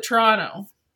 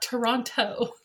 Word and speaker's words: Toronto: She pronounced the second T. So anybody from Ontario calls it Toronto. – Okay. – Not Toronto. – I guess I think Toronto when I say it. Yeah. – Toronto? – Yeah. – No, Toronto. – Toronto? Am I saying that wrong too? Toronto? – No, that's better.